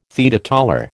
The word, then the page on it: teetotaller: American English